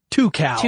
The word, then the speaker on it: Tucows: Yeah, two cows. – Two cows? – Yep. Yeah, they have two cows, twice as many cows as someone who has one cow. So yeah, except it's spelled T-U-C-O-W-S.